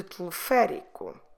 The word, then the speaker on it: teleférico: Teleférico. Como se escreve teleférico? T-E-L-E-F-E, com acento, R-I-C-O, teleférico.